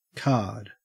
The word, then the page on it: card: Australian English